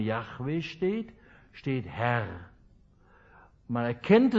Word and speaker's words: Yahweh: Früher sagte man Jehova. Yahweh muss das eigentlich ausgesprochen werden.